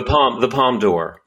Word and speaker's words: Palme d'Or: How do you is it Palme d'Or? Is that how you say it? – The Palme d'Or.